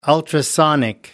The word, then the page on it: ultrasonic: American English